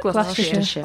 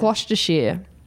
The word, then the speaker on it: Gloucestershire: Gloucestershire. – Gloucestershire. – Just gloss. – Glossy. – To. – Gloucestershire. Gloucestershire. Gloucestershire.